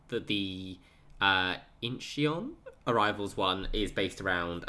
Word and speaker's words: Incheon: I think it's pronounced Incheon. – Incheon. – Incheon. Yeah. Like, awesome. Inchn. Incheon. – Incheon. – Yeah, according to Google.